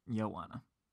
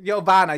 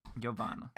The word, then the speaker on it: Giovanna: It's Joanna, but Giovanna, you got to put the V on it, girl. Giovanna.